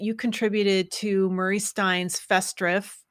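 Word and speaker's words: Festschrift: That Festdrift, is that how the correct pronunciation? – Yes. – Do you know? – Yes. Fest is festive, like festive. – Mm-hmm. – And Schrift is like written in the sense of something written, a book.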